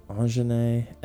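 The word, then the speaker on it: Aunjanue: Angene Ellis. It's the way I've heard it pronounced, but anyway.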